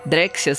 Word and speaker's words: Drexciya: Drexia se escreve D-R-E-X-C-I-Y-A.